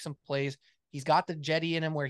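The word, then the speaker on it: Cedi: It's pronounced with a J, by the way, like Jedi, Jetty.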